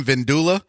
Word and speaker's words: vindaloo: If you're feeling like Indian food, you get some of the best butter chicken in Houston or you get the lamb vindaloo.